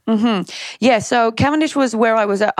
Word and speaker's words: Cavendish: Let's go back to England, to Cavendish. Is that how you say it? Yep.